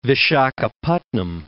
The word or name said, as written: Vishakhapatnam